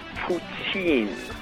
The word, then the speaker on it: poutine: And second of all, Tim, it's pronounced poutine.